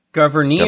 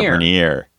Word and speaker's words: Gouverneur: The most probable and correct pronunciation comes from Abigail Adams, who is known for her phonetic writings. She wrote his name as Gouverneur. – Oh, gosh. Gouverneur. – Gouverneur.